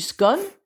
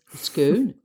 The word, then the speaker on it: Scone: Alors voilà, typiquement britannique, on a deux mots qui s'écrivent presque pareil, mais on les prononce différemment. Stone of Scone, on pourrait dire Stone of Scone.